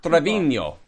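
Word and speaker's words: Treviño: Nicholas Trevino. Yeah? Mm-hmm. Yes. There's a little thing above the end, so I know that's how you say it.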